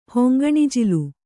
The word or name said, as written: ಹೊಂಗಣಜಿಲು